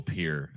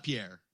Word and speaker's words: Pierre: Yeah, we call our South Dakota the state capital Pier. I mean, we butchered that shit. You're just going to get something more dumb and literally pronounced. – Yeah, we did. It's not Pierre. It's Pier.